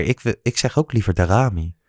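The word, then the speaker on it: Daramy: Waarom komt Darami er niet in? Gewoon, je doet alleen maar Klaassen voor Berghuis. Spreek je zijn naam zo uit trouwens? – Ja, Darami volgens mij.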